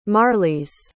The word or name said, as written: marlis